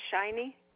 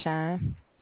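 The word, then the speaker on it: Shyne: And it's pronounced shiny? – Shine, just shine. – Oh, just shine.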